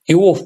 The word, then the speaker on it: Иов: Тоже по-разному люди ставят ударение. Иов, по-моему, правильнее.